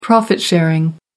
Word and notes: profit sharing: American English

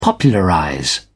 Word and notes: popularize: British English

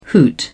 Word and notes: hoot: British English

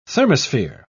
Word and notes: thermosphere: British English

